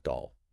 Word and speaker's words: Daahl: And just a quick note, that is spelled D-A-A-H-L, but it's pronounced Dahl, like the toy.